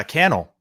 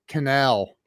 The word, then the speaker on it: Cannell: Super grateful to be here, fired up, and it's uh Cannell rhymes with YouTube channel, so we're good to go. I, love, I, love it, dude. So I, I I don't know why I kept wanting to say canal, and that's that's what most people say.